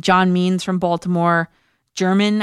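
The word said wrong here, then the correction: Germán: I think you pronounce it German, Marquez, Colorado, Michael Pineda, Minnesota, Kyle Gibson, Texas, Jon Gray, Colorado. Those are the teams I'm looking to be picking from. – Herman Marquez. I like that.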